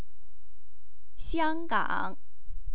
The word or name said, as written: xianggang